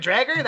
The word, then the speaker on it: Dreger: His name's Dredger. Is that his name? Is that how you say it? – Dredger? – Darren Dreger, that asshole? No, I'm Darren Dreger, man.